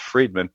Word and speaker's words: Fridman: Fridman is how it's spelled, F-R-I-D, but I do believe it's pronounced Fridman.